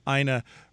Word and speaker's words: Ina: you start by the little helper that you're the first, your name, Ina, rhymes with vagina.